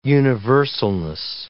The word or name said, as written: universalness